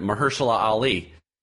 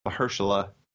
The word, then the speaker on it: Mahershala: We learned how to say his name at the Critics' Choice Awards. – Mm-hmm. – We learned it. Mahershala Ali. – Mahershala.